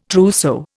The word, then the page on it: trousseau: Indian